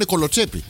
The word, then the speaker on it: κολοτσέπι: Εν τω μεταξύ, το τονίζουμε λάθος. Κανονικά πρέπει να είναι κολοτσέπι.